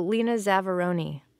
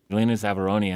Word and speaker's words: Lena Zavaroni: Oh, Lena Zavaroni. – Lena Zavaroni and her cover version of a Neil Sedaka song called Going Nowhere.